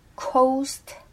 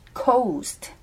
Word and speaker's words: coast: 근데 coast라는 그 OA, OA 그 모음 자체가 사실 한국 사람들한테는 발음하기가 좀 어려운 부분이에요. 그러니까 우리는 그냥 오 아니면 어 이게 있잖아요. 오 아니면 아. 그죠? 근데 어 오를 생각하시면 될 것 같아요. 그냥 오. cost. 그죠? cost 아닙니다.